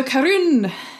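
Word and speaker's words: Caorunn: So Caorun is made up in Scotland, which why you have to roll your R's.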